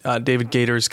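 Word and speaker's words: Gaider: And you had already brought up David Gator, that's how you pronounce his name, David Gator's comments.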